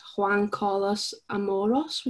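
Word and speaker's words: Juan Carlos Amorós: but it's Juan Carlos Amoros. Would you say that right? – I'd go with that, you know. That was quite good.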